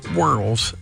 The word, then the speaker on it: whorls: And so that's the first syllable, arches, loops, or whorls, W-H-O-R-L-S. It could be pronounced whorls or whirls, some people say.